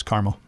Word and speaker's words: Carmel: You pronounce it Carmel, right? – Yeah, here it's Carmel.